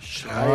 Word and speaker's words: Schreiber: A pronúncia é Schreiber? – Schreiber significa escrivão em alemão. Schreiber.